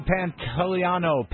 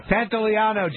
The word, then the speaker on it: Pantoliano: On Friday, we'll have actor Joe Pantoliano. Why am I saying it wrong? – Pantoliano.